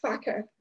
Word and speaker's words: Farquhar: We know up here, it, yeah, it should pronounce it "facker." Now, there's obviously reasons why they did this, because there are probably complaints going to Ofcom if they pronounce it the Scottish way.